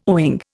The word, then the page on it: owing: Indian